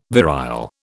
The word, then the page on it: virile: British English